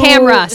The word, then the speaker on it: Tamra: Tamara who Tamra, sorry. Says that you pronounce it Tamara. I was like, bitch, how do you spell that? – Yeah.